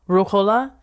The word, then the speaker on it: Ruhollah: How do you pronounce it? Rohala? Was it Rohallah? – Ruhollah. So he was Ruhollah Khomeini at this time, and he is exiled.